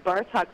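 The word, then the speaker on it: Bartók: It starts out with a Romanian dance number called Bartok. Am I pronouncing that right? – Yes, Bartok, Bella Bartok.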